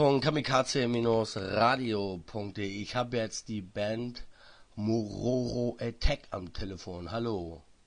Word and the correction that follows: Mururoa Attack: Das spricht niemand richtig aus. Es heißt eigentlich Mururoa Attack. Das ist dieses kleine Atoll da in der Südsee, wo die Franzosen vor 15, 16 Jahren ihre letzte Bombe haben fallen lassen.